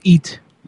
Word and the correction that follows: heat: With heat. There's an H. You have to pronounce it.